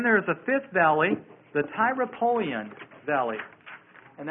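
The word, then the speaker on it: Tyropoeon: In between the Tyropolean Valley and the Kidron Valley is Mount Zion.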